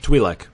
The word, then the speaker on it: Twi'lek: Is it Twi'lek? – Twi'lek. – Yes.